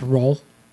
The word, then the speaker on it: Rouen: Day four is a sailing day, and you end in Rouen, or as they say, they told us over and over on the ship, it's pronounced Roll. And on day five, you are still in Roll.